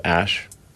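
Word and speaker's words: Æ: I mean, it's just X, the letter X. The AE is like pronounced Ash.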